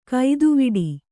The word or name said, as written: ಗತಿವಡೆ